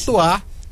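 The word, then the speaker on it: Chez toi: Chetois, _ em seu lugar. Chetois se escreve Chez, e Toar se escreve T-O-I.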